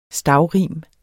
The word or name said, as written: STAVRIMET